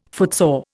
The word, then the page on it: footsore: Indian